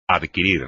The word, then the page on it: adquirir: espagnol